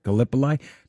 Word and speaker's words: Gallipoli: Because of, how do you say, Gallipoli? – Gallipoli.